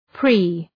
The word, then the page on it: pre-: pri: